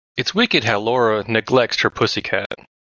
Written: neglects

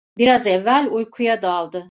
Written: evvel

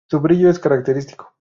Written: brillo

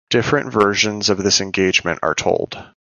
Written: versions

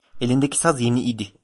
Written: saz

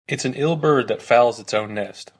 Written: fouls

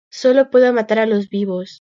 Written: matar

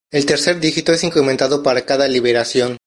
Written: incrementado

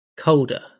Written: colder

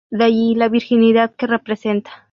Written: virginidad

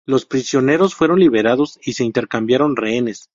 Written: intercambiaron

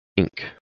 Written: Inc

